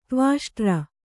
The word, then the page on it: ತ್ವಾಷ್ಟ್ರ: tvāṣṭra